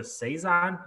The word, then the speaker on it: saison: It's a it's a saison. Am I saying that right? Saison.